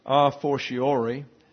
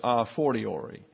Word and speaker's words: a fortiori: The first pronunciation by Webster's is a fortiori. Also, the pronunciation secondly is a fortiori. So you may choose either one. A fortiori is the one I'm familiar with, but I have heard a fortiori, as maybe you have.